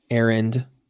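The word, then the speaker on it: Arend: Yeah, just search Aaron Richard. My name is spelled a little weird. It's A-R-E-N-D, as in dog, Aaron Richard, and it'll come up.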